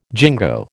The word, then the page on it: jingo: American English